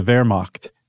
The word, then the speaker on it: Wehrmacht: And these were like high-ranking military officials, some of the highest military officials in the German military, the Wehrmacht. Saying that right? – Sure.